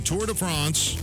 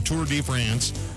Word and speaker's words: Tour de France: He won the Tour de France, or Tour de France, again. Depends on how you say it.